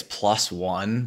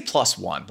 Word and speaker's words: PLOS: I want to say it's plus one, but it's spelled P-L-O-S. Let's say plus one. Let's go with that.